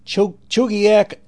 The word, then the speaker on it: Chugiak: A new station in Chugiak, Alaska, I believe is how you pronounce it, C-H-U-G-I-A-K is the spelling. Like I said, 1160 on the dial, K-H-F-T is their new call. 1170 W-L-Y-G, Hanceville, Alabama, has made the switch to W-Q-H-C.